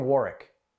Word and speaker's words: Warwick: But if you're from Rhode Island, you can say, oh, I'm from Warwick. You don't pronounce the second W for some reason.